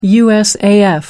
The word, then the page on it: usaf: American English